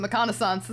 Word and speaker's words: McConaissance: Because I'm pronouncing it wrong. It's the McConaughey-sance. – Nope, it's the McConaissance.